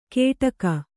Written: ಕೇಟಕ

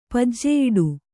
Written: ಪಜ್ಜೆಯಿಡು

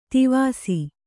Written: ತಿವಿಸು

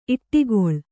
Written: ಈಟಿಗಾಳ

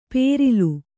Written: ಪೇರಿಲು

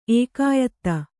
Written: ಏಕಾಯತ

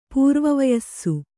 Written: ಪೂರ್ವವಯಸ್ಸು

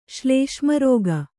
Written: ಶ್ಲೇಷ್ಮರೋಗ

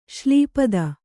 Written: ಶೈಲಪತಿ

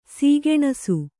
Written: ಸುಕನಾಸಿ

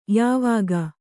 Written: ಯಾವಾಕೆ

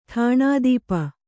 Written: ಠಾಣಾದೀಪ

